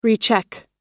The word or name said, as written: recheck